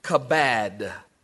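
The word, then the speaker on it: kabad (כבד): The word honor is the Hebrew word kabad. I'll spell it K A B A D, Kabad.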